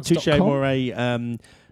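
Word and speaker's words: Touché Amoré: Touche Amore Layment. – Okay, ready? Okay, one sec. – Did I say that wrong? – Touche Amore. Touche Amore is correct.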